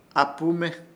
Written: AHPUME

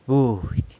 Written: budy

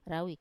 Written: rawi

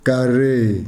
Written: karee

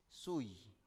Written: sui

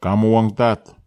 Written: kamoag tat

